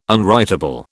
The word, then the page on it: unwritable: British English